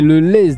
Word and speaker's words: le Lez: C'est pour ça qu'a été inventé à Castelnau-le-Nez, je pense que ça se prononce comme ça, ou alors le nez. – Non, c'est le lez déjà, tonton, c'est pas un N, c'est un L.